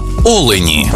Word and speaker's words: олені: в однині, і в множині наголос падає на перший склад – олень та олені.